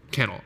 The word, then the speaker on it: Cannell: Is it Cannell or Cannell? I feel like it's Cannell. – Cannell. – Okay. I've been saying it wrong my entire life.